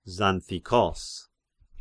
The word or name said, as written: Xanthikos